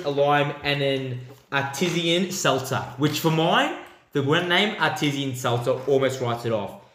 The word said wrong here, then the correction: artisan: But yeah, so artisan, I believe, is the pronunciation of that word. – Isn't it artesian? – No. – It's artisan. Artisan.